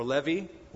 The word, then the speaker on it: Levi: The son of Mathat, the son of Levi, or Levi would be the correct pronunciation.